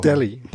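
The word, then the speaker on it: Dele: Delhi. Delhi, Delhi Ali. So wie in äh, die Essensläden in New York.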